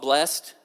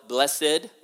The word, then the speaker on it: blessed: And that word, we've taken it and we've kind of put two syllables in it. You know what I mean? It, some people call it blessed, others call it blessed, but it is the same word.